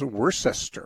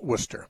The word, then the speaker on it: Worcester: First time I went to Worcester, I couldn't pronounce it. It looked like it was Worcester. Of course, I never could pronounce Worcestershire sauce either. It's just spelled funny. But now I know it's Worcester.